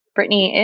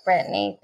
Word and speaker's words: Brittney: Brittany is spelled BRITTNEY.